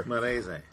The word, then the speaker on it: Castellammarese: It was called the Castellammarese War. Again, I don't know how to pronounce Italian words. Castellammarese War. – Marese. – Marese.